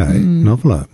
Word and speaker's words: NOVLR: Noveler.